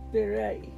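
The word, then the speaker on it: Be'eri: But it's B-E-apostrophe-E-R-I. So it looks like Beery. – Yeah. – But it's Beery.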